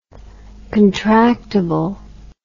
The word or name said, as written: contractable